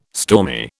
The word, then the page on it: stormy: British English